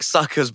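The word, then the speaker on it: PSUKers: I quite like suckers, but the P is silent.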